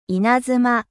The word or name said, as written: eclair